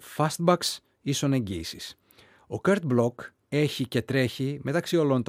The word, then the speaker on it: Bloch: Το καινούργιο συγκλάκι των 33 Lovers που λέγεται Free, για τον Kurt Block που προφέρεται έτσι αλλά γράφεται B-L-O-C-H.